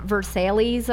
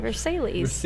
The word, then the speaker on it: Versailles: The Palace of Versailles or whatever? We're gonna go see that. – I think that's how it's pronounced. – Yeah, too. – Versailles.